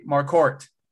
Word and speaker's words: Marquardt: Marquardt. Like a basketball court. – Charlie Marquardt.